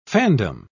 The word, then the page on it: fandom: British English